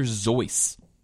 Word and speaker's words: Seuss: It's not Dr. Seuss. It's Dr. Zoice. That's how it's about, supposed to be pronounced. – But it's a pseudonym, so I guess you can say it's Zeus. – He said it was Zeus. – Whoa. – Yeah, he said it rhymes with voice or choice.